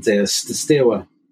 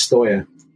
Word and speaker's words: Steaua: Now, I'm just going to emphasize the pronunciation because I think I think through various television commentators over the years have pronounced them as Steaua, which is how it reads to us in English, S-T-E-A-U-A. And I think that's stuck. But the actual pronunciation in Romanian is Steaua Bucharest, because apparently Steaua, if you say Steaua in Romanian, it is a slang word for male genitalia.